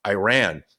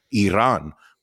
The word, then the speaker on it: Iran: I understand if they're living in a country where Iran, or as you like to pronounce it, Iran, where the internet is censored, where you would not have been able to get this information.